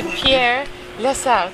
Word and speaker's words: Pierre Lessard: And I bumped into Pierre Lessard.